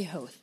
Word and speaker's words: Howth: The locals say Howth, so we'll go with Howth.